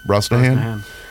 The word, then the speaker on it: Brosnahan: Brosnahan.